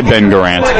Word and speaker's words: Garant: I think most people just say Ben Garant. – Ben Garant. But doesn't he have an extraneous first name or no? – He does say Robert Ben Garant is the official one.